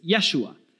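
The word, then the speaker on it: Yeshua (ישוע): And Jesus' name in Aramaic, some of you might know this, is Yeshua.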